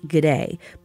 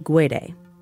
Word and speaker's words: Guede: You might also hear it pronounced G'day, but Rudy himself pronounces it Guede.